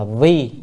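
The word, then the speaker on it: vi (為): thì chữ đó nên đọc là vi, là con nay vi là làm, con nay hành Bồ Tát đạo.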